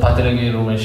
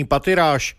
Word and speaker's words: Pattirage: Pattirage. – Vyslovoval své jméno Rumesh Pattirage a vyloučil anglickou výslovnost svého příjmení Pattiráž.